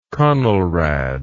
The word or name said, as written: conelrad